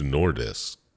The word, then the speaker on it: Nordisk: To Nordisk. How do you say that? – Nordisk, N-O-R-D-I-S-K.